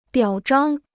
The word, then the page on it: 表彰: biǎo zhāng